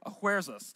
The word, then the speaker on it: Ahasuerus: Now, you'll notice some translations, you saw this earlier, will say Xerxes, others will say Ahasuerus. That's the Hebrew pronunciation of his name, and it sounds, to a Hebrew speaker, it would sound a little bit like King Headache.